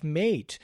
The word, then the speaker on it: mate: If it's M-A-T-E with the accent, you are correct in your pronunciation. He's referring to the fact that I usually pronounce it mate. And if it's spelled M-A-T-E without the accent, Bill gets the gold star.